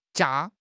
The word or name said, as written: jia1